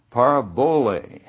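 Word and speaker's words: parabole (παραβολή): Now, the Greek word for parable is parabole, and you can probably hear that sounds like our English word parable when you pronounce the Greek word.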